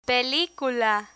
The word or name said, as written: pelicula